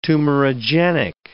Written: tumorigenic

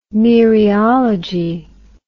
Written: mereology

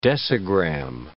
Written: decigram

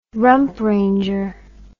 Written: rump ranger

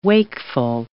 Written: wakeful

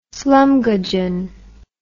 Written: slumgudgeon

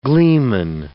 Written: gleeman

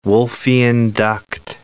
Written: Wolffian duct

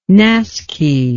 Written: naskhi

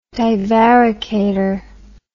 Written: divaricator

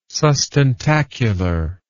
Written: sustentacular

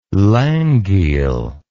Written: langeel

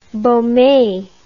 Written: beaume

